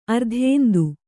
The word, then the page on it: ಅರ್ಧೇಂದು: ardhēndu